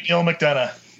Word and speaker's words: Neil McDonough: Neil McDonald? How do you say that? – Oh, he plays a bad guy in everything. – The steely blue-eyed Neil McDonough.